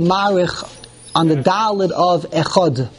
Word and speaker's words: echad: So the answer is we don't pronounce the dalet correctly. The real way it's echad. It's a th because there is no dot in the dalet. We read the dalet as if there's a dot, echod.